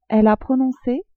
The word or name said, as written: elle a prononcé